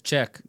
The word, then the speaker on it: Czech: Yeah, it's Czech, like checkmate.